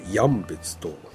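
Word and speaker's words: 止別: これあの札幌の札に、それから弓辺に弦ですか、これでさ、鶴と、まあ、読ませるんですね。あともう一つ、ヤンベツっていう、どういう字書くかこう言葉だけじゃわからんと思うんですね、はい。 – これ、まあ、止まるっていう字ですね。これに分かれるでヤンベツと読みますね。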